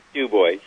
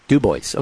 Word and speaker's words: Dubois: Dubois or Dubois? – It's Dubois. – Dubois, okay.